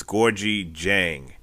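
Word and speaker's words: Dieng: So the D is pronounced with a J. So it's Gorgui Jang.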